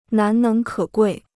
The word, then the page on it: 难能可贵: nán néng kě guì